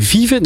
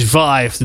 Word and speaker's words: Vive: Ik zat even te kijken. Vive? Nee, het is Vive natuurlijk. wij gaan Amerikaans.